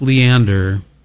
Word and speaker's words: Leander: In addition, the most interesting thing about this song is the guy who wrote the song, Mike Leander, if that's how you pronounce it.